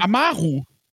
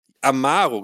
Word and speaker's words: Amaru: Wie wird der ausgesprochen? – Amaru. – Amaru, genau, Joshua Amaru.